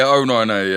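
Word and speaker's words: O9A: Is it 09A? Is that how it's pronounced? – Yeah, 09A, yeah.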